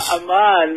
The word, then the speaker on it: Amann: Ja, Amann, mit der Betonung auf der zweiten Silbe.